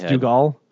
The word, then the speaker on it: Dougal: Is it pronounced Dougal? – No, you're right.